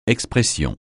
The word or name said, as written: expression